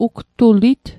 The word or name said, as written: ugtulit